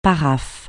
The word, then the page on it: paraphe: francais